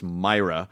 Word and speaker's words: Mira: But Matt, one of Matt's biggest crosses to bear is that his last name is pronounced Myra. – Yeah. – But of course, in Los Angeles, Mira.